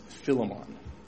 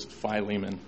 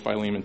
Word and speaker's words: Philemon: Now, someone actually told me it's pronounced Philemon. But we're just going to use the standard American Midwestern pronunciation. The one I grew up with is Philemon. So whether it's Fleming, Philemon, or Philemon, we're going to go with Philemon today.